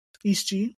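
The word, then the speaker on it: Est Gee: Yeah, so moving on, we got Est G. Is that how you say it? – East G. Yeah, East G.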